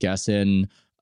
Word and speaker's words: Gessen: Now, I should have added a little more texture to it, where her husband is the successful writer Keith Gessen, who, that's how I pronounce his last name.